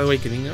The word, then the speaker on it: Awakening: Es el álbum Awakening del año, no sé si lo dije bien. Awakening, ¿no? Sí, es correcto, Awakening, ¿no? Como Link's Awakening, creo que sí, ¿no?